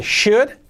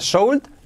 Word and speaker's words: should: Deberías, should, pero modal, should, no se pronuncia sold, no, should, sin la L.